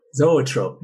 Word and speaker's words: Zoetrope: Zoetrope, right? – Zoetrope.